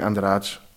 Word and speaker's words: Andrade: Es kommt darauf an, woher du aus Brasilien bist wahrscheinlich. Aber wir Cariocas aus Rio würden sagen Andrade oder Andrade irgend so. – Ja, so. Klingt ja gut, wir nehmen das. – Mit viel G und I.